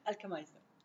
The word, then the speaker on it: Alkhamayseh: Alkhamayseh.